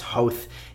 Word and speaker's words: Howth: Howth.